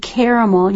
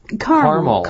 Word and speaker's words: caramel: But they hand out hard caramel. You may hear some people say caramel. – Right. – It's yeah. – pronounced both ways.